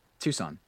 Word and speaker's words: Tucson: Tucson. – Tucson.